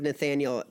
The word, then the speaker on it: Nathanael: Like, my full name is Nathaniel, and it's spelled A-E-L.